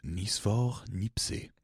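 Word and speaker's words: Nicéphore Niépce: Joseph Niesfor Nipse.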